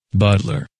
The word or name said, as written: Butler